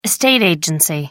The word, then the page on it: estate agency: American English